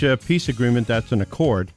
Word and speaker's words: Accord: Heather Ballard is in Accord. – Accord, yeah.